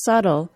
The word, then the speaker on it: subtle: The first interesting thing about the pronunciation of the word subtle is that silent B in the middle of the word, S-U-B-T-L-E. Why is that B there?